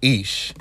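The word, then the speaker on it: Ish (איש): And so, however, in Job chapter 1, verse 1, it begins with Ish. Ish is the Hebrew word for man. And I mentioned it in the last podcast, but when you talk about the word Ish, is spelled Aleph Yud Shin.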